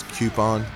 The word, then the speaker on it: coupon: The way I pronounce coupon, it's coupon. Not coupon, coupon. There's a Q in there.